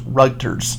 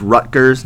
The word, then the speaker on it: Rutgers: Until I was 18 years old, I thought that Rutgers was pronounced Rugters. R-U-G-T rather than R-U-T-G. I don't know why, but I remember vividly where I was when someone told me I was pronouncing it wrong. I was mind blown. – It is Rutgers, named after Henry Rutgers, and it is the official state university of New Jersey.